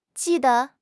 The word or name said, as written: ji4 de5